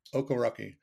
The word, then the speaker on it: Okereke: And I think it's Okereke. – Okereke.